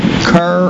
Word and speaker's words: Kerr: And my only big win as a pro in North Carolina came from Kerr or Carr Reservoir, depending on how you say it.